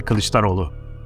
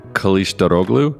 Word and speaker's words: Kılıçdaroğlu: It's Kemal Kılıçdaroğlu. – Kılıçdaroğlu? Is that yes. – Right? – Yes. You're doing better than I thought.